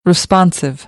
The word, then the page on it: responsive: American English; ɑː